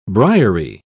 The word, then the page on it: briery: British English